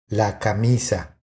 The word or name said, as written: La camisa